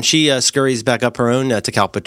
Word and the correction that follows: catalpa: tree, is that how you pronounce it? – Catalpa, yeah. Catalpa, okay. And when she goes up her tree, if I may say, she'll have a minute.